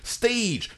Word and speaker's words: Stage: So we watch Stage, colon, the culinary internship. It's pronounced stage. Mm, it's, it's, it's written Stage, colon, the culinary it, internship. It's pronounced stage. it's written Stage, I'm the culinary so we watched this uh, this documentary about kids who work for free for nine months.